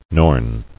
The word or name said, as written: Norn